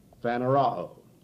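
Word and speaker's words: phaneroō: that's spelled P-H-A-N-E-R-O-O, and the first O is short, like in box, and the last O is long, like in moon, and we would pronounce it phanerao. P-H-A-N-E-R-O-O.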